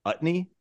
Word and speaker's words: Utne: Utney, is that how you pronounce it? Utney? – Yep. – Hell yeah, first try.